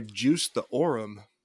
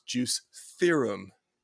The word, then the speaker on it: Juice Theorem: But I got to tell a funny story because I saw this one and I was like, oh, what do they mean by Juice the Aurum? Juice the Aurum, like Aurum, Utah. What does that mean? Juice Aurum? Did they juiced Aurum? I was like, oh, wow, you're dumb. That's Juice Theorem. It's spelled the same.